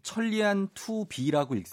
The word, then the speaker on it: 천리안 2B: 그리고 어, 지금 천리안 2B라고 읽습니까? – 예, 예 우리나라 2B.